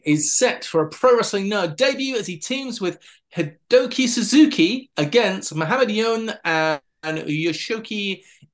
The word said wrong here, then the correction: Hideki Suzuki: I know it's Hideki Suzuki, former NXT trainer, of course.